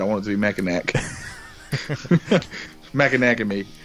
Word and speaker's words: Mackinac: It's actually Mackinac. – Well, why is there a C at the end? – I know. I said the same thing. But it's pronounced Mackinac Island. It's Native American. It's Native American, I think.